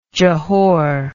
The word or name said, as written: Johore